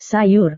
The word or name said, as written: sayur